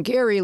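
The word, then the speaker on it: Gerry: He spells it Jerry, but pronounces it Gary.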